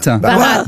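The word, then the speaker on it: Bharat: Qu'est-ce qu'on devrait normalement bientôt tous appeler Barat? – Marat? – Barat.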